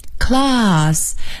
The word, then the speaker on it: class: 上课这个课，或者是也可以做班级来讲，是 class, C-L-A-S-S, class, class。那么这个啊，英式英语的读法呢，它是 A 读成啊，那么在这里的 class 这个 C-L-A-S-S 中间的 A 读成啊 class。